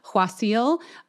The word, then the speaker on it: Qasil: I think I had a guest co-host that time, Jess, so you might not remember this, but Kwasil, if you pronounce it correctly, it has that kind of huh sound to it, but it's spelled Q-A-S-I-L.